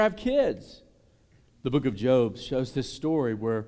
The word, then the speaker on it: Job: I'll never forget, as a college student, I was in a Bible study, and I called this the book of Job, right? It's spelled J-O-B, by the way. And some Christian people made fun of me. It's the book of Job. Well, it's spelled J-O-B, son.